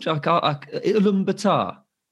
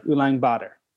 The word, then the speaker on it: Ulaanbaatar: Ulaanbaatar. – The A is shorter, so it's like it's spelled, you'd think it'd be like Ulaanbaatar, but it's like Ulan, there's almost like a G sound, like Ulaanbaatar, like Ulaanbaatar.